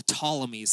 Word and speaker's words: Ptolemies: And the kingdom in the south is called the Ptolemies. That's how you pronounce that weird P word up there, the Ptolemaic dynasty.